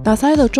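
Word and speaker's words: 獺祭: 我们这期就应景说说酒的故事，聊聊日本的清酒品牌大塞。打塞的中文或者是说它的汉字写作是塔记，它在日文读音呢就是刚刚说到的打塞。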